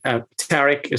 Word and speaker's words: Tariq: Tariq is fine, and I'm really excited to be a part of this.